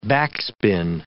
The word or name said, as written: backspin